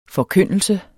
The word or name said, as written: forkyndelse